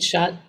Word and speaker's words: Schutt: Kate, my name is Kate Shutt. My last name is spelled S-C-H-U-T-T, but I pronounce it like shut the door.